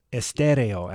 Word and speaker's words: Estéreo: Estéreo, actually. The stress is on the second syllable.